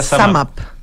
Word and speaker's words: SumUp: Se escribe Sumup, ¿ya? S-U-M-U-P, pero se pronuncia Sumap.